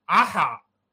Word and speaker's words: عحع: قلت له في عند المصريه القديمه كلمه انا مش عارف لغايه دلوقتي متهيألي ما فيش عربي يقدر ينطقها بسلاسه، عين حا عين، دي عندهم كتير قوي عحع وكتير قوي.